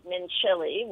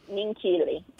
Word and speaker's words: Minchilli: Well, you were pronouncing it Mincilli, which mm-hmm. – is how it's spelled, M-I-N-C-H-I-L-L-I. – Right. – But the C-H is actually hard, so it's Mincilli.